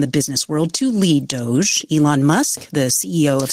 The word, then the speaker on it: Doge: The pronunciation of Doge is about the shitcoin known as Doge. Otherwise, it would be Doge or something. So the fact that it's Doge is completely connects it to that.